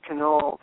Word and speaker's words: Canole: She says people pronounce it in a variety of ways. She said people that love the Italian pastries call it cannoli, but it's canole. It's, I think it's Irish or Scottish.